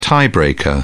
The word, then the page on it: tie breaker: British English